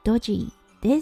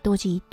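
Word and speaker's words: Dodgy: 今日のワードはドジーです。はい、これ、ドッジボールのドッジに GY をつけてドジーと読むんですね。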